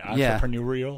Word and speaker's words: entrepreneurial: Did I say that word right? Entrepreneurial? – Yeah. – How do you say that word? – Entrepreneurial. – That entrepreneurial spirit.